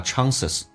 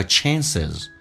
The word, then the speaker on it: chances: 我们来看一下第一个 chances，那么这个发音在美式叫做发 a，英式发音叫 chances，美式发音叫 chances，